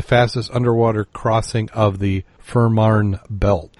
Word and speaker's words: Fehmarn: F-E-H-M-A-R-N, Marn, Fehmarn.